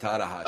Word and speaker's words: Tanahashi: Hiroshi Tanahashi? – Yeah, that's it. Tanahashi.